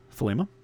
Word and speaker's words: thelema: Uh, it gets mispronounced a lot too. Thelema is uh, Thelema. Man, I've heard it pronounced a whole bunch of different ways, but pronunciation I usually go with is Thelema.